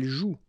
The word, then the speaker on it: joue: so you've got this after, but then clearly you don't pronounce it. Okay, elle joue. And then, je skie.